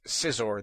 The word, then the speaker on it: Scizor: Same thing with Scizor, the evolution of Scyther. And also, Scyther is also mispronounced. I've heard people saying Scizor and Scyther. Again, the C is silent.